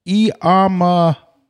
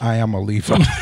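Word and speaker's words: Iamaleava: But Nico Iamalieva. Not Iamalieva.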